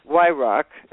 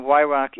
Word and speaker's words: Weyhrauch: Yrock, okay, and his name, it's a tough one to spell, but I'm going to spell it for you. Brent, B-R-E-N-T, and Yrock is W-E-Y-H-R-A-U-C-H. I'm going to spell it again, Yrock, W-E-Y-H-R-A-U-C-H.